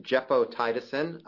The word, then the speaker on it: gepotidacin: It is pronounced gepotidacin.